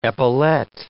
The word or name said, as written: epaulet